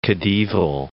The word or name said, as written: khedival